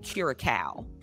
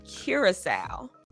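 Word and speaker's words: Curaçao: But everybody always pronounces it like Curacao. But it's actually Curacao. It's like a Dutch island in the Caribbean.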